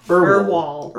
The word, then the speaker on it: Urwol: yeah, then you can, like, glance at, a pair of beds and, what did I say his name? Orwell? – Erwall. – U-R-W-O-L.